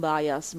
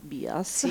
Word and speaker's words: bias: E l'origine del termine bias cognitivo, io mi ostino a pronunciarlo in italiano, non bias, ma bias.